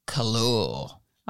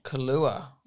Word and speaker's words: Kahlua: And equal part of coffee liqueur, which is Kahlua. – Oh, don't start that again. Say it properly. – Kahlua. – Thank Kahlua? You.